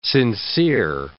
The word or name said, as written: sincere